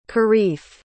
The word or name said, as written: kareef